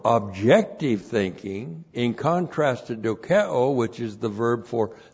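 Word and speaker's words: dokeo (δοκέω): D-O-K-E long O, dokeo, that's subjective thinking.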